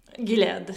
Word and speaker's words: Gilead: E, ed viene prodotto da quello che ho capito solo dalla Gilead, Gilead come si legge? – Gilead, sì.